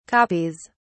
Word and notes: copies: British English

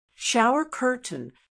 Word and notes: shower curtain: American English

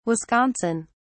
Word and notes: Wisconsin: British English

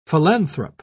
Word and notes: philanthrope: British English

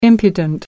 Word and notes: impudent: American English

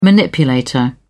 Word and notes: manipulator: British English

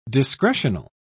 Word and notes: discretional: British English

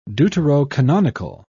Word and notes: deuterocanonical: British English

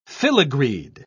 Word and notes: filigreed: British English

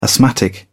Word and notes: asthmatic: British English

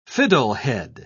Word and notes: fiddlehead: British English

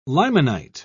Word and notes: limonite: British English

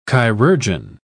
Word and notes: chirurgeon: British English

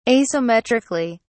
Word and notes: asymmetrically: British English

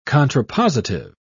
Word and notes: contrapositive: British English

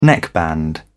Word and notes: neckband: British English

